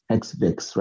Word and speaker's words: HXVX: Yeah, in terms of HXVX now, like uh, my supervisor, he pronounces it as hex vex, right? Hex cause and vex and the vexing about the cause rights of Africa.